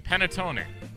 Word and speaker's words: panettone: This one, Chef Swagger, Panettone. – Oh, nice pronunciation.